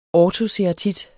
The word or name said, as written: ortoceratit